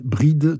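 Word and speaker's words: Bridet: Ono się pisze przez T na końcu, ale gdyby, ale wymawia się tak samo jak przymiotnik Bride i tam by było bez tego T na końcu i z E z akcentem wymawiane E, Bride.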